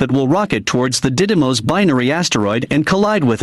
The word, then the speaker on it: Didymos: And the mission involves NASA launching a spacecraft that will rocket towards the Didymos, D-I-D-Y-M-O-S, binary asteroid and collide with it.